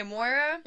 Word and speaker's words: Moira: But okay, Moira.